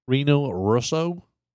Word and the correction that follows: Rene Russo: How do you say the name of Russo? – Rene Russo.